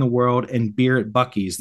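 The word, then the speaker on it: Buc-ee's: And his text back was Bucky's, pronounced Buck-E's.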